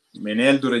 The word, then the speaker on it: Meneldur: Ecco, c'era questo re, eh, Meneldur, giusto l'accento? – Meneldur è giusto.